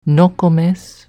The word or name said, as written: Nokomes